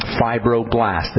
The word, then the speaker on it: fibroblast: Before you turn on the fibroblast, the cell itself, and fibroblast, by the way, is spelled F-I-B-R-O, fibro, as in fibers, blast, B-L-A-S-T, fibroblast. This is a cell that makes the collagen,